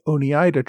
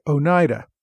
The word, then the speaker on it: Oneida: A couple of weeks ago, I started talking about the Iroquois tribes and referred to the Oneida tribe. Listeners informed me that, once again, I botched the correct pronunciation of a word. It is, in fact, Oneida.